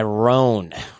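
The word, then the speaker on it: Rhone: This episode is also sponsored by Roan. Roan is spelled like Arhone.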